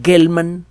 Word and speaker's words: Gell-Mann: Eh, Murray Gell-Mann George Zweig, se escribe Z-W-E-I-G. El, segundo Murray Gelman, como suena, G-E-L-L-M-A-N-N. Por cierto, resultó ser un buen divulgador.